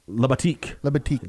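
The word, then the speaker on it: Libatique: I can't say his name. Liberwicks. – Labatique. That's what I'm saying.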